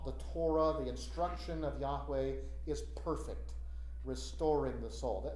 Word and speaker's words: Yahweh: I'm using the name Yahweh now. Jehovah is the old way of pronouncing it.